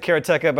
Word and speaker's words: Karateka: And so, first of all, Jordan Mechner does pronounce it karateka, but I still don't like that. I thought it was karateka. It yeah. should be. – Because it's a real word, Japanese word that just means someone who practices karate. I don't know why adding ka at the end would change the pronunciation of the word.